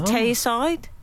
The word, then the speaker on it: Tayside: He says Tayside. Is that pronounced incorrectly? – No, that's right.